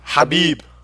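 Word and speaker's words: Khabib: Habib. Habib Blank. – Yeah, the craziest part about that is that he doesn't pronounce name. He doesn't pronounce the K.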